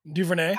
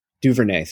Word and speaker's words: DuVernay: DuVernay. – How do you say her name? DuVernay.